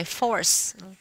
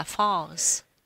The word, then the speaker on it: false: Là cô phát âm là cái từ force, mà thầy bảo không phải force mà là false, là cái từ là giả và hiện ra và biến mất.